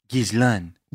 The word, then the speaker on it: Ghislaine: Well, we did pull up like a French pronunciation video and it was like Ghislaine. – Ghislaine Maxwell. – Well, we call her Ghislaine.